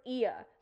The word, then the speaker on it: Oia: O-I-A? – In brackets, I wrote Ia. So that's how you would say it, but it's spelled O-I-A.